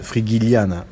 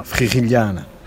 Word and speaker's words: Frigiliana: Autrement, on peut la prononcer à la française, Frigiliana, mais c'est vraiment Frigiliana.